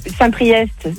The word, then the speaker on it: Saint-Priest: Saint-Priest, c'est EST, tu le prononces.